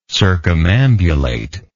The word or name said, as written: circumambulatory